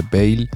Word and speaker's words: Veyl: Puf esto es, esto es, pero Canela en Rama, menudo recopilatorio que se marcan los amigos de Bale. Eh, se escribe V-E-Y-L, el sello. No como el futbolista.